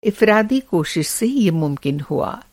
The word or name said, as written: इफ़्रादी